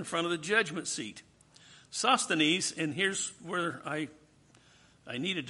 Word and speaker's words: Sosthenes: Sosthenes.